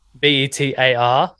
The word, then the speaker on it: Betar: The podcast is called The Better Project. Now, it's not spelt how you would typically spell better. It's spelled my last name. So Betar.